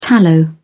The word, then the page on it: tallow: British English